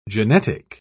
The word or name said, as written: genetic